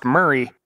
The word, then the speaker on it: Maury: And remember, Murray County is where I've been turkey hunting for a couple of years now, and that's spelled M-A-U-R-Y. – You are the father. – For all of us northern folks, that's pronounced Murray in Tennessee, as in Bill.